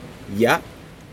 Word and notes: ya: Aneityum

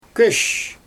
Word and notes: köösh: Xyzyl

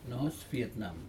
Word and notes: nohos vietnam: Aneityum

